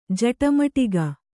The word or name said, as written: ಜಟಮಟಿಕ